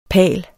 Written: palen

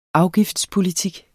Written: afgiftspolitik

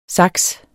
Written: SAKSENES

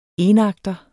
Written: enakter

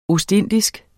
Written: ostindisk

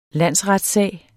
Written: landsretssag

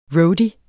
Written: roadie

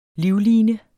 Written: livline

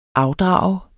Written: afdrag

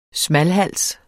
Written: smalhans

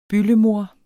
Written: byldemoder

